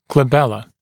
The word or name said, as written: glabella